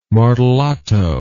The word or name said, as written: martellato